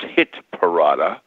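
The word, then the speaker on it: Hitparade: and I used to do a show called the Hit Parade, which is pronounced Hit Parada,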